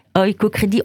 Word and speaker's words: Oikocredit: Donc, Et oicocredit s'écrit comment, s'il te plaît Écoute Oiko, O-I-K-O, C-R-E-D-I-T. Oiko Crédit,